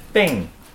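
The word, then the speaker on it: Bing (病): So it just Bing, ing. It's a high pitch down to a lower pitch. Okay. Ing, ing. – You're, what? – That's how you pronounce it. Bing.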